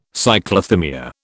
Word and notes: Cyclothymia: British English

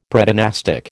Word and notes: Predynastic: American English

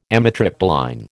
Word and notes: amitriptyline: American English

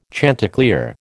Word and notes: chanticleer: American English

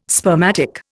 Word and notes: spermatic: Indian

